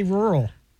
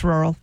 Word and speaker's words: rural: How do you guys say rural? – It's rural. – Rural. – It's like one syllable.